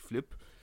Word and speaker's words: Flip: Viele Medien haben Philipp geschrieben, aber das stimmt tatsächlich nicht, er heißt tatsächlich Flip.